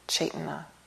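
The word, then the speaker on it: cetanā: I like to do that because it's really important to understand with sankhara the root factor of mind of chetana in Pali, which is often translated as volition or intention, chetana, which is spelled C-E-T-A-N-A.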